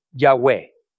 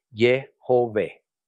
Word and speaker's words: Yahweh: La pronunciación Yahweh, por tanto, es menos probable porque tiene dos sílabas y emplea solo dos sonidos vocálicos. Más correcto es probablemente Yehové.